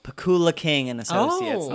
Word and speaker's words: Pakula: I tweeted today, can someone tell me how to pronounce Pakula? – It's Pakula.